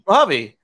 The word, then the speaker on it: Mojave: Okay, how do you pronounce that? That's in California as well. Mojave? M-O-J-A-V-E. – Mojave. – Mojave. – Yeah. As in that Mojave one's kind of Spanish, so I'll give them as in the Mojave Desert.